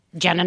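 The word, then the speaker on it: Jena: Jenna Not Jameson. One N. My parents spelled my name wrong. – Okay. – Thank you very much. I've been here all night. – Like Gina? It's like a Gina. – They spelled it like, it's J-E-N-A.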